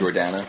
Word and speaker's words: Jordana: Jordana.